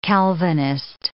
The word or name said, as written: Calvinist